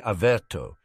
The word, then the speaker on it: Averto: Now, using the exact same wand movements, cast the spell again, only saying Averto instead of Protego. The accent is on the second syllable, which is pronounced to rhyme with air, with a slight trill on the R.